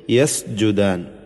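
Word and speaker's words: യസ്ജുദാൻ (يسجدان): വന്നജ്മു വശ്ശജറു യസ്ജുദാൻ. യസ് എന്ന സീനിൻ്റെ ഉച്ചാരണം അല്പം കൂടി വ്യക്തമാവണം. യസ്ജുദാൻ എന്ന് പറയുന്നത് പോലെ ആവരുത്, അതായത് ഒരു സായിൻ്റെ ശബ്ദമായി പോവരുത്, മറിച്ച് യസ്ജുദാൻ, യസ്ജുദാൻ അല്ല യസ്ജുദാൻ.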